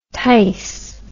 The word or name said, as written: tice